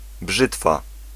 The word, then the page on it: mes: [ˈmɛs]